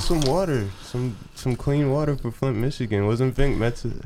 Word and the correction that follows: Vic Mensa: heavy? How you say it? – Vic Mensa.